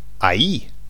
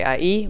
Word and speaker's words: ahí: Okay, but then we've got ahí. – Ahí. Okay, ahí, which is spelled A H I with with an accent. Ahí. Ahí is more like oh ahí.